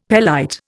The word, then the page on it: pelite: Indian